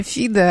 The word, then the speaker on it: ФИДЕ: А ФИДЕ, я заметила, что ударение ФИДЕ, оказывается, так выучили, запомнили.